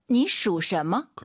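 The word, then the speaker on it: 你属什么: 니슈什么? – 그렇죠. 발음 잘하셔야 돼요. 니슈什么? 시 쓰면 안 돼요.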